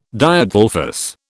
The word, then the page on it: Diadelphous: British English